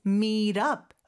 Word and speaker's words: meet up: meet 하고 up은 meet up 이렇게 연음이 되는데요. 연음이 되면서 t가 모음 소리 사이에 끼죠. 그래서 real처럼 부드럽게 발음해 주시면 돼요. Let's meet up, up 이렇게 발음이 되죠.